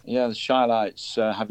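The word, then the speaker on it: Chi-Lites: The Chillites have popped into my head for some reason. – You've got the name wrong for a start. It's the Shylights.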